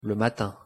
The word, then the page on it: le matin: French